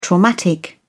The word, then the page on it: traumatic: British English